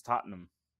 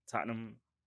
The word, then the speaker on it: Tottenham: It's Tottenham. Tottenham. Right? Yeah, but it's not pronounced that way. That's how they suss you out. You know what I mean? If you're talking about it in conversation, it'd be like you'd say it's Tottenham.